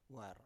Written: waru

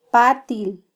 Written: patil

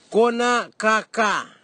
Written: konakaka

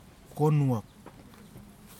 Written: konuwak

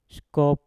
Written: skop

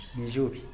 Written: nizhuby

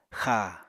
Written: xa

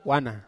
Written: ana

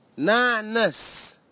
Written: nanis

